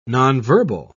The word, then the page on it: nonverbal: British English